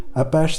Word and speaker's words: Apache: En fait, c'était Apache, c'est pas tellement le nom des Indiens, c'est que c'est devenu Apache en anglais, ça se prononce Apache.